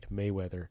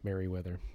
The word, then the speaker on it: Mayweather: It took me the longest time to get his name right, Mayweather. You know, not Merryweather. But anyway, it's funny because often I'll hear other people saying it and laughing because that's not his name.